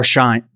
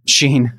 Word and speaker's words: Shein: Sheen. – Sheen. It's it's very interesting. Their name is spelled S-H-E-I-N. And so it's Sheen, not Shine, but it is easy to mix up. Yeah. – Sheen like Charlie.